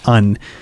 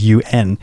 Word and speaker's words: Un: I should say that's Un, because I've been struggling to figure out what that, how you should pronounce it. It's just the letters U-N. And I finally found on their Facebook page that it's Un Like Sun.